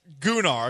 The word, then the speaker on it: Gunnar: It's pronounced Gunnar because it's G-U-N-N-A-R, not to be confused with Gooning.